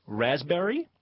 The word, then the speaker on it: raspberry: K-N-E-E-L, Neil. – Raspberry, answer. – Silent letters. – Yes, silent letters. Raspberry is... – The silent S. – P. – Well, P. Sorry. – Silent P, right.